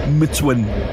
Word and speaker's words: MTWN: I think the artist is, it's spelled MTWN, which makes you want to say Motown. Mitwin. Mitwin. Mitwin.